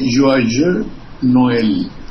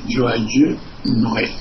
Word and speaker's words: Joyeux Noël: Sí, pregunto, se dice, es difícil pronunciar y yo tengo una pronunciación de perro, se dice Joyeux Noël. – Ah, sí, es cierto. – Alegre Navidad, Joyeux Noël.